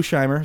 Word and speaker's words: Scheimer: Lou Scheimer, is that how you say it? – Yeah.